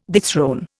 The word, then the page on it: dethrone: Indian